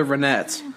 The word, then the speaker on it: Ronettes: Is it the Ronettes or the Ronettes? – I think it's Ronettes. Oh, no. Let's get this going. – I swear, this is one of those parody pronounce. – Ronettes. – Okay, they're the Ronettes.